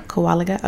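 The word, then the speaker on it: Kowaliga: Kowalgi. – Mm-hmm. – Am I saying it right, guys? – Yeah. – Kowalgi.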